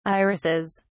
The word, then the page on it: irises: British English